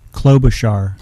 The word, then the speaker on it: Klobuchar: How do I pronounce Amy's name again? – Klobuchar. – Klobuchar.